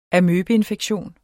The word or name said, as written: amøbeinfektion